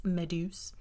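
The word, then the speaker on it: Méduse: I'm going to call it Medusa, but in French it's Meduse.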